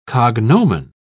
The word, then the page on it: cognomen: British English